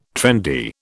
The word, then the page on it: trendy: British English